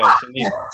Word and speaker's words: Shynikia: I have Shynikia. Yeah, is that how I say it? That is It's Shynikia. – Okay, Shynikia.